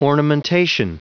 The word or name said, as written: ornamentation